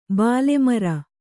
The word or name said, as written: ಬಾಲೆಮರ